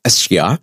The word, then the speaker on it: Esja: But you'll see Mount Esya, E-S-J-A, pronounced Esya, and that's across the bay.